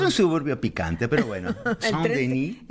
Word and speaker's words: Saint-Denis: Qué bien pronunciar Saint-Denis. – Saint-Denis.